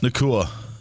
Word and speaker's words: Nacua: Nacua.